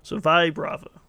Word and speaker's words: Vibrava: Also, a trainer says it like this in the show at one point, so Vibrava, which makes sense because vibrate, but we'll get to that later.